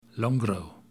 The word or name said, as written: Longrow